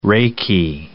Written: Reiki

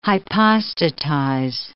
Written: hypostatize

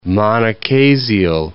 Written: monochasial

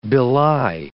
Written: belie

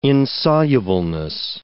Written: insolubleness

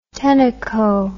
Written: tenneco

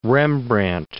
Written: Rembrandt

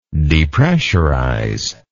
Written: depressurizer

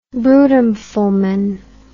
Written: brutum fulmen

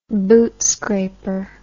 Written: bootscraper

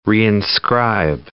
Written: reinscribe

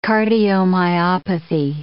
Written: cardiomyopathy

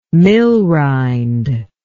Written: millrind

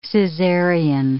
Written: cesarean

